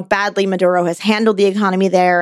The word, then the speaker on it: Maduro: So he went on an extended kind of criticism of Venezuela, of Nicolas Maduro, you know, the president of Venezuela.